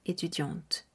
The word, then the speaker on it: étudiante: Pero la pronunciación será étudiante.